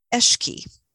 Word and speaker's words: Alki: And the way she pronounced it was Eshki. And I'm probably not really doing justice to that, but Eshki, with a little bit of gurgle in the back of your mouth, you know, Eshki.